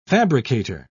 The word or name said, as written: fabricator